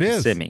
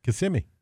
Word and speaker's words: Kissimmee: Yes, and that's how you say it. – It Kissimmee. – Is. Kissimmee. Yep.